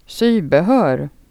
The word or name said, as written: affären